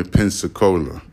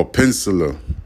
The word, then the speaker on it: Peninsula: I'm gonna say it like this, the California Pensacola, all right? Oh, Peninsula. The California Peninsula. Oh, Peninsula.